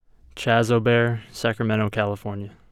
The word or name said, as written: Chas O'Bear